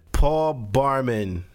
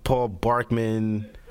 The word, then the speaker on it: Barman: Alright, does Paul Barman am I saying it right? Paul Barkman? Barman? Okay, Paul Barman. – Paul Barman. At least I know how to say his name. Everybody else seems to be fucking up his name lately. They're calling like Paul Barkman.